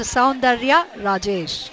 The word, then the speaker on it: Saundarya Rajesh: I am Dr. Saundarya Rajesh.